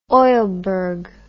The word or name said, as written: oilberg